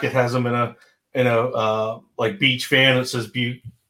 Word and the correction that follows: Buc-ee's: I almost did it again. Buc-ee's are bust. – What the hell? You're literally because it's spelled Buc-ee.